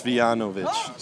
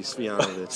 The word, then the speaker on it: Svijanović: It's Svijanović. – Svijanović. – Yes, the Y, the J is like a Y. – Okay. – It's easy. Svijanović.